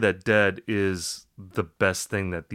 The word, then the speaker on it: Dehd: Dead. Dead is a band, and you know, it sounds like The Grateful Dead, but it's just Dead, D-E-H-D is how it's spelled.